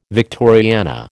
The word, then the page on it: Victoriana: American English